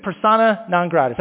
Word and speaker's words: persona non grata: If I say, help me out, if I say persona non grata. That means he's not well-liked, right? Did I say that correctly? Help me out. Thank you, Bill. Yes, thank you. He is persona non grata.